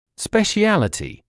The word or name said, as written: speciality